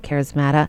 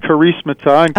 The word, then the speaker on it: charismata: Did I say that right, charismata? – You're close. – You can go ahead and correct me, and then tell me about what that is. Is it just a group that of charismatic renewal college-age students that get together, or... – Right. Well, it's pronounced charismata.